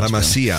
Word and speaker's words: La Masia: I don't know how you pronounce that. – La Masia, I would say.